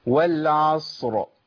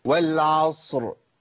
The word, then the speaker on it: والعصر: زي ايه؟ والعصر، انا عندي الصاد عص، صاد ساكنه بعديها راء ساكنه للوقف، ما لو وقفت عليها اقول والعصر، عص صاد ساكنه وراء ساكنه هوصلها بيها مع التسكين، هقول ايه؟ والعصر.